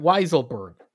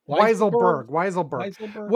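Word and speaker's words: Weisselberg: Did Donald Trump direct this activity, or was, how do you say his name? Weisselman? – What? Weiselberg. – Weiselberg.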